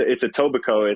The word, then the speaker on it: Etobicoke: No, it's Etobicoke.